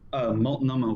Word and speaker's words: Multnomah: Multnomah Whiskey Library.